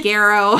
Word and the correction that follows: garrote: I suck at pronunciation with these things. It's a garrote, okay?